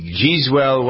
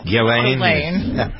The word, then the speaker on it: Ghislaine: Number 10, the Giswell, why don't I never pronounce it? – Giswell. – Ghislaine.